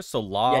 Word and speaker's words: Solaris: Am I saying it right there? Solaris? – Yeah, now you're saying it right.